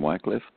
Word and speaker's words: Wycliffe: By the way, you'll hear his pronunciation two ways, Wycliffe and Wycliffe. And I think Wycliffe is more of like the American English translation or pronunciation. So some of the references I've seen that are a little bit older, the pronunciation comes across as Wycliffe.